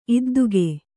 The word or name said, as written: ಇದ್ದುಗೆ